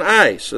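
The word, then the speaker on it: ice (εἰς): the Greek preposition "ice," and I believe it's pronounced I-C-E, just like cold ice.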